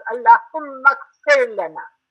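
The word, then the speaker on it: اللهم اغفر لنا: If it was Allahumma and then Aghfir, you pronounce it separately, but you have to elide, you have to run those into each other, so it becomes Allahumma Aghfir Lana.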